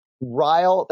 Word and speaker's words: Ryle: And Ryle, that's spelled R-Y-L-E. – Yeah, so J.C. Ryle. – J.C. Ryle, that's right.